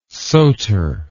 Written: soter

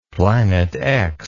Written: planet x

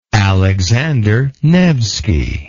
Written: Alexander Nevski

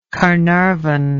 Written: Caernarvon

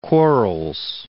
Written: Quarles

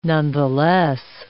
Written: nonetheless

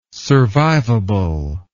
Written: survivable

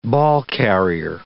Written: ballcarrier

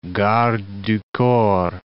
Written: garde du corps

